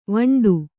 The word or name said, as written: ವಿಂಡೆ